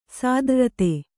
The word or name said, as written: ಸಾದ್ರ್ರತೆ